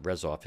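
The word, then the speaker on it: Rzeszów: There was a big story in the New York Times yesterday about where I first started off, Yezhov, which is, or I would pronounce it Rezov, it depends, but that's where the main airport is.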